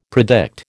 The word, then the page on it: predict: American English